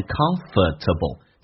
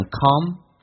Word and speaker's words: comfortable: 在美式发音当中，这个单词我们知道很多人会发成 comfortable，但美式发音直接把 or 全部的拿掉变成 comfortable， comfortable， alright you see that，所以听力又会形成了一个不敏感对这个单词。